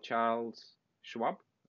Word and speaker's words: Charles Schwab: That example was about Charles Schwab.